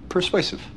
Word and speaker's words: persuasive: Nota que estamos acentuando la segunda sílaba. Persuasive. Persuasive.